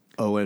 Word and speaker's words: Eoin: is it actually pronounced? Appar- allegedly, it's Oh, it's Owen. Owen. Owen.